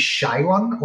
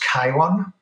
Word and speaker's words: Chiron: So the website, my name and my company is Chiron, although I know in US it's pronounced Chiron.